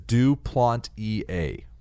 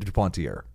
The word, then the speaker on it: Duplantier: According to Adam Azer, it is Duplantier. – Yeah. So I said it right. – No, you said Duplantier.